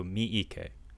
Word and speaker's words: Miike: There's two E's in there, so Miike. – Miike. Takashi Miike.